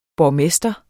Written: borgmester

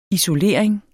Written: isolering

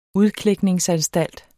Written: udklækningsanstalt

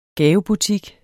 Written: GAVEBUTIK

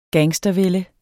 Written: gangstervælde

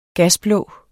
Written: gasblå

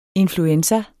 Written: influenza

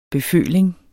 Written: beføling

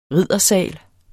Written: RIDDERSAL

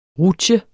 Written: rutsje

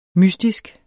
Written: mystisk

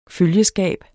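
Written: følgeskab

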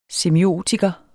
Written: semiotiker

